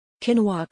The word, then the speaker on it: quinoa: Quinoa Quinoa Quinoa, pronounced quinoa is a superfood that has only gained popularity over the last few years.